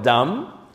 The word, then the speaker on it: dam: ¿Cómo se dice sangre en hebreo? Dam.